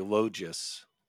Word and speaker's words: Logis: Oh, it's a long O, and it's like a G is like a J, Logis.